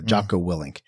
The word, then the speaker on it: Jocko Willink: I think that's how you say his name. He's like a former U.S. Navy SEAL. He has a podcast. I think it's pronounced Jocko Willink.